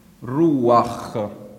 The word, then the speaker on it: ruach: La parola ebraica è ruach. Ruach. Questa A piccola perché non si sente quasi nella pronuncia. Ruach. Significa spirito. L'accento è qua. Ruach, non ruach. Ruach.